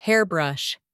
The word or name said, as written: hair brush